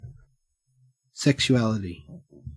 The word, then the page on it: sexuality: Australian English